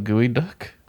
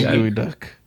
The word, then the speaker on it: geoduck: So it's spelled geoduck. I would like both of you to look up Be Nasty Niggas. – What, look up gooey duck? – Yeah.